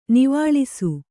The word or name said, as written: ನೀವಾಳಿಸು